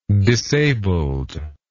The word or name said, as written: disabled